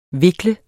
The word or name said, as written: vikle